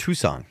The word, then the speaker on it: Tucson: C-A-N? – No, that's Tuscan. – No, it's C-O-N. – The city is pronounced Tucson.